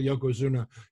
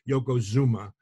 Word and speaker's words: Yokozuna: So I think I call Yokozuna Yokozuma. But I also think that Mr. Fuji calls him Yokozuma, if I'm not mistaken.